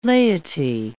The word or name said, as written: laity